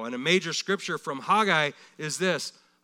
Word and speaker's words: Haggai: It's pronounced Haggai, Haggai.